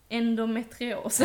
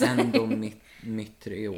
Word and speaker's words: endometrios: Endometrios. – Endometrios.